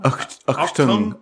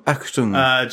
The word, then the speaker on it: Achtung: Edward Dragansky writes, Achtung, is that how you say it? Achtung? – Achtung. Achtung.